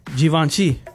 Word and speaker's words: Givenchy: Givenchy. – I've never even heard of that brand. – I get, you're supposed to pronounce it with a Z, according to this. It's Z-H-E-E-V-O-N-S-H-E-E. Givenchy. Givenchy.